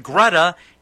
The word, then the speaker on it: Greta: text Greta, G-R-E-T-A, it rhymes with feta, like cheese, to get into win a pair of tickets before they go on sale.